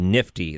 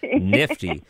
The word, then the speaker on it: NFTE: NFTE, Nifty. That's a great way to pronounce it. I love that. Nifty.